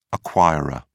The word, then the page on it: acquirer: British English